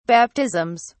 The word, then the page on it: baptisms: British English